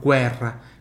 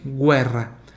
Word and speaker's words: guerra: olha como é que eu falo guerra, se escreve igual ao português, mas se fala diferente, guerra, guerra, tem o som do U ali, tá?